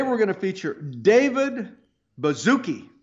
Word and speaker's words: Baszucki: And we know how to pronounce this. We know how to pronounce this, Doc, because it's spelled funny. It's B-A-S-Z-U-C-K-I. But we know how to pronounce it because we have a very easy way to remember. Just think of bubblegum.